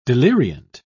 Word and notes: deliriant: British English

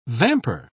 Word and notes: vamper: British English

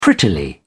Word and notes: prettily: British English

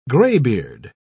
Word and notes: graybeard: British English

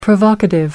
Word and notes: provocative: American English

